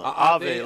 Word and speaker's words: Ave: Ave tequila. Ave, like Ave Maria.